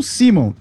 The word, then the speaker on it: Szymon: O polonês se escreve S-Z-Y-M-O-N. Eu já meti um Simon.